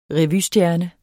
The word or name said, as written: revystjerne